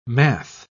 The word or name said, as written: math